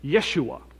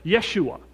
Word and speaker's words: Yeshua: But really, if you were to pronounce that in the right way, it's the name Yeshua, the name Joshua. They don't have J's in Greek or in Hebrew, so they would pronounce it with a Y, right? Yeshua.